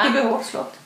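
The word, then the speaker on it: Ekebyhovs slott: Nu är vi på Ekerö slott, heter det, va? Ekebohovslott. – Ekerö bohovslott. – Ekebohovslott.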